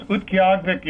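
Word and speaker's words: Utqiaġvik: Uktiavik is the pronunciation that we all struggled with when they first changed the name, but my wife works at the Alaska University of Alaska Fairbanks Native Language Center, and so I happen to know the Inupiat instructor at UAF, so I asked him to confirm my pronunciation.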